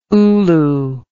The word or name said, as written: ulu